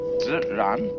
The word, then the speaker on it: ziran: We use the word nature for the Chinese expression zi, zi ran. They pronounce J like a sort of unrolled R.